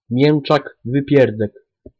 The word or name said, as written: mieczak wypierdek